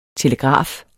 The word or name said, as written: telegraf